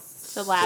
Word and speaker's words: syllabic: There's some symbolic, how do you say? – Symbolic. – No. – Syllabic. – Syllable. Syllabic. There, thank you. Syllabic. I was saying symbolic.